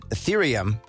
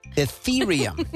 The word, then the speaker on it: Ethereum: I probably would have pronounced it Ethereum, but Ethereum.